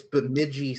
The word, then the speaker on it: Bemidji: I think it's Bemidji State. – Yeah, we went through this. We went through the different college hockey teams once before, and that was like the one we got hung up on. – I'm sure name wise, she'll be able to correct me. – You got it.